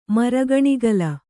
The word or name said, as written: ಮರಗಣಿಗಲ